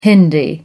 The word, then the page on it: Hindi: American English